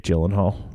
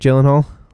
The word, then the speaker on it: Gyllenhaal: I'll give you my real opinion if he looks like Jake Gyllenhaal, if you're one of those people that pronounces the G, mm-hmm. – the hard G in Gyllenhaal.